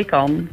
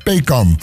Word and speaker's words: PKAN: En hij leidt aan een genetische ziekte, het PKAN. Zeg ik dat goed? – Ja, PKAN. – PKAN.